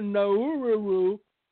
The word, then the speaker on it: Nauru: N-A-U-R-U, Nauru, Palau, Marshall Islands, Guatemala, and Honduras.